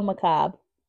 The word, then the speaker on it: macabre: How's it spelled? – M-A-C-B-R-E, I think. – B-R-E? And it's pronounced macabre? – How do you spell macabre? Oh, M-A-C-A-B-R-E. – I hate when English just do stupid shit like that. It's because from, why? – It was a French word.